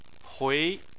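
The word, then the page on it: hui: tone 2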